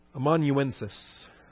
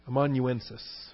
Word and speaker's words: amanuensis: Amanuensis. Amanuensis. Although I've seen it accented in different syllables. Commonly, amanuensis.